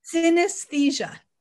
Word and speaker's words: synesthesia: Synesthesia.